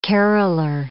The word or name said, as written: caroller